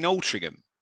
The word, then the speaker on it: Altrincham: He was born on the 26th of August, 1971, in Outrigium. – Outrigium. – You mean Altrincham? – Yeah, sorry, I said it wrong.